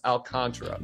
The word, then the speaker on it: Alcantara: Nobody knows how to say his name properly. It's Alcantara.